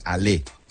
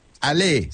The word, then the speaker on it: Allez: Here we go Steelers, es Ale Steelers, A-L-L-E-Z, se pronuncia Ale, Ale Steelers.